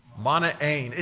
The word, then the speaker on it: Manaen: By the way, can you say that name the way I've pronounced it there? Say it. Manaen. It just doesn't ring true in our pronunciation, but that's how you say it in Greek.